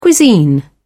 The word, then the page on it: cuisine: British English